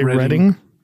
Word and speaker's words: Reading: Oh, Greg throws the dice and lands right on Reading Railroad, or I'm assuming you say Reading. – That's how you pronounce it.